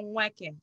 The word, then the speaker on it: Nweke: You swallow the N, so it's Ifu Nanya Nweke.